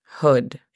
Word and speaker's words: hood: La pronunciación fonética es algo compleja porque aunque se lee J-U-D, realmente no es hood, es hood. Es algo, es una U un poco más abierta. A ver, ¿nos puedes hacer la pronunciación fonética? Hood. Hood, exacto.